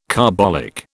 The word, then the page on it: carbolic: British English